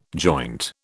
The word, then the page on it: joint: British English